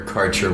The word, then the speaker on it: Karcher: And mine is Sam underscore Karcher one K A R C H E R.